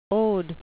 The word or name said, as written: ode